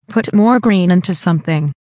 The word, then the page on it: put more green into something: American English